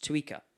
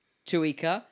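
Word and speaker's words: țuică: T-S-U-I-C-A. – Pardon? – T-S? T-S. Is it S silent, is it? – Yeah. No, it's pronounced Tuica.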